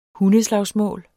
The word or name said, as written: hundeslagsmål